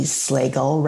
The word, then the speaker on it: Slagle: Hello, Ali Slagle, rhymes with bagel.